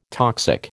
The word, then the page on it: toxic: American English